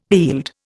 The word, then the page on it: heald: Indian